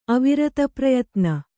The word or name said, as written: ಅವಿರತಪ್ರಯತ್ನ